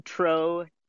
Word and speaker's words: Troshynski: So my parents break it up to say Troshynski.